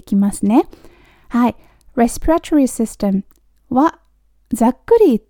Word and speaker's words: respiratory: このね、 respiratory っていう単語、オーストラリアでは多分 respiratory ってみんな発音している気がするんですけれど、アメリカのね、 YouTube とかね、見るとみんな respiratory って言ってる気がします。なんか res の方にアクセントがすごくついて respiratory。